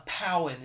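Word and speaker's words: Pau'an: A Powan, and a Powan is how you pronounce it, from Utapau.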